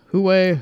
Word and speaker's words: Huawei: Several tech blogs are reporting that that is the case and citing leaks that Chinese manufacturer Huawei is that how you pronounce it? Huawei? – That's how we're pronouncing it.